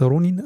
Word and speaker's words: Ronin: Ähm, dann würde ein äh, Ronin, ein, ein Ronin, wie spricht man das? Ronin? Ronin, ne? R. Im Japanischen sind wir weiter vorne.